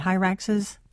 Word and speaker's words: hyraxes: Well, let me play again a very short clip, and this is one of the hyraxes. Is that how you say it, hyraxes? plural? – That is, yes.